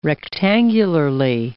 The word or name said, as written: rectangularly